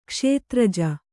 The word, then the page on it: ಕ್ಷೇತ್ರಜ: kṣētraja